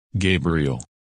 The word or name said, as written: Gabriel